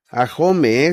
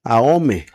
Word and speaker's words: Ahome: Bueno, esta señora está severamente preocupada porque no se pronuncia Ahome, es Ahome.